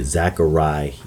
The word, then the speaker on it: Zechariah: And the book that I'm referring to is the book of Zechariah. The book of Zechariah, or as some would enunciate it, Zechariah, to put emphasis on the end of the name, which it articulates the Lord God's name as Yah or Yahweh.